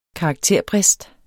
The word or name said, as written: karakterbrist